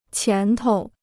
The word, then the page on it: 前头: qián tou